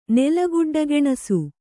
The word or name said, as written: ನೆಲಗುಡ್ಡಗೆಣಸು